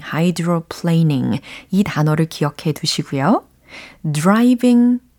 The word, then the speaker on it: hydroplaning: 음, 자, 먼저 수막 현상은 hydroplaning 이렇게 표현을 해보시면 좋겠어요. 특히 이 plane 들으셨죠? plane. 여기에서 ing로 바꿔서 planing이라고 발음을 한 거거든요.